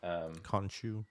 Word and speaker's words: Khonshu: Khonshu.